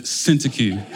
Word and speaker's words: Syntyche: So I looked up various pronunciations for Syntyche, and I settled with the Brooklyn pronunciation. There was Syntyche, there was another one that was in the Greek, but tonight we're going with Syntyche, okay?